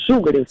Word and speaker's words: шугаринг: Поэтому ударение в нем нужно ставить на первый слог и говорить правильно еще раз. Как? – «Шугаринг».